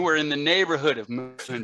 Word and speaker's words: München: If we're talking about like uh, cities that are mispronounced by Americans or English speakers, Munich is one of the biggest ones, which I've never understood why we pronounce it the way that we do when it's actually pronounced München.